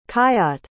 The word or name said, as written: khayyat